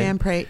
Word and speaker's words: Mampre: Is that how he pronounces his name? – Mampre. He's Armenian.